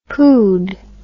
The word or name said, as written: poohed